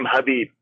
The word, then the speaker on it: Khabib: Yeah, and by the way, you pronounce his name Khabib. There's no K.